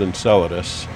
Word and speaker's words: Enceladus: It's called Enceladus. And if you forget the name, just think enchiladas and pronounce it a little differently.